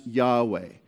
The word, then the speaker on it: Yahweh: Now, when he says, I am the Lord your God, in the Hebrew, those are the four letters, YHWH, which we generally pronounce Yahweh.